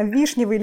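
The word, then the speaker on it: вишнёвый: Дорогие наши слушатели, напишите в комментариях, как правильно, вишневый или вишневый. Ваше мнение, только чур не гуглить. – Ну подождите, там же есть буква Ё. Всегда в слове ударение на букву Ё, если она есть.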